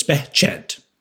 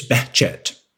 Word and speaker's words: Behçet: So in Turkey and in Arabic-speaking countries, the H sound is stronger, so it's Behçet. Behçet, which is hard for Anglophone people to speak, but it's technically it's Behçet. That's how they say it.